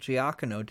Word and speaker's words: Giacchino: for the original Doctor Strange, it was Michael Giacchino did the music.